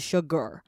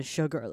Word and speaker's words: sugar: 好，那在念的时候它其实就是 sugar 听起来有点像那个 ER 的感觉，就其实如果你要念 AR 的话，它念标准一点是 sugar，但是因为你要念快一点，你要让它好听，它其实发音被调整成 sugar 了。